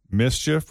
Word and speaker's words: MSCHF: It's from the brand Mischief, which is spelled M-S-C-H-F.